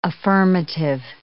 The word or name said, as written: affirmative